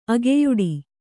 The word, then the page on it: ಅಗೆಯೊಡೆ: ageyoḍe